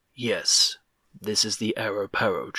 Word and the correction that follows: Arapaho: tribe. – Arapaho!